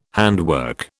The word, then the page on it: handwork: British English